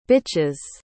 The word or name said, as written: bitches